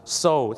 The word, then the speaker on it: sew: so